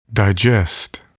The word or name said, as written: digest